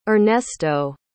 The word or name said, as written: Ernesto